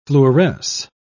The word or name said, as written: fluoresce